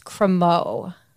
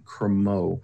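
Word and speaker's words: crémeux: Pronounced Cremeau. – Is it Cremeau? – It's French. – Golden coffee Cremeau. – I think EUX is pronounced O. – Oh, so golden coffee Cremeau.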